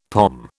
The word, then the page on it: pom: British English